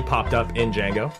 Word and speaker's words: Django: It's Django Unchained. The D is silent.